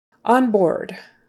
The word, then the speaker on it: onboard: On board. On, on, board, or, or. It's spelled O A R D, but it's just kind of like an O R, ORD.